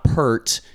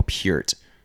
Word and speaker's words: Peart: That's how you pronounce his name. It's not Pert, it's Neil Peart.